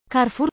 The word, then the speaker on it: Carrefour: Carrefour czyta się z A, bo to francuski supermarket.